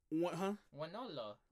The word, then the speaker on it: Wonolo: Why you put an R in it? One Orlo. – I don't know, because that's just how how I pronounce it, one Orlo. – Yeah. – Because it's a weird-ass word, W-O, Wonolo. – Wonolo, yeah. Wonolo.